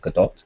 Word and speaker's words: Gadot: I realize that you apparently pronounce her surname Gadot. Gadot always seems like more stylish to me, so I'm going to keep calling her Gadot.